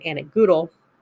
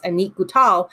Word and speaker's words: Annick Goutal: Whereas my Annick Goutal Rose Pompon Eau de Toilette, which I found out I was pronouncing that name wrong. I've been pronouncing it Annick Goutal. Sorry, it's Annick Goutal.